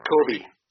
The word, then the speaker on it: Koby: Co- is it Cody? Co- Cody or Cody? I always screw that name. – Kobe. Kobe. Kobe. K O B Y. Yeah, I got it. I know how to spell it once you said Kobe.